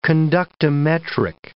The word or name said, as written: conductimetric